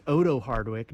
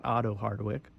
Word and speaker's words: Otto Hardwick: And I learned it's Odo Hardwick, not Otto Hardwick.